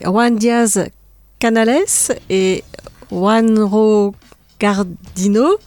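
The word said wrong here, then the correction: Guarnido: Non, Garnido. Ah mince, j'ai fait une photo que je corrige sur le, sur le blog. C'est Garnido.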